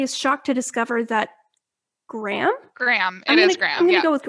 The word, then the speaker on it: Graeme: It's definitely Graham. – Perfect. So for anyone unique who doesn't spelling. Have the book in front of them, it's spelled G-R-A-E-M-E.